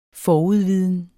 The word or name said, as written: forudviden